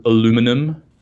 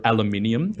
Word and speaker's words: aluminium: You know, you get this beautiful, shiny new piece of, you know, milled aluminum, as you Americans say, or aluminium.